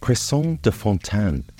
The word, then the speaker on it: cresson de fontaine: Watercress, or yellow cress as it is also known, is pronounced cresson de fontaine in French, and spelt cresson, C-R-E-S-S-O-N, de, fontaine. F-O-N-T-A-I-N-E.